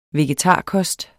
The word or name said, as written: vegetarkost